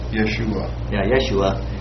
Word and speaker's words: Yeshua: And this one, according to the analytical Greek lexicon, the first one, pronounce it again. – Yeshua.